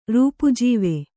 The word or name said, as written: ರೂಪಾಜೀವೆ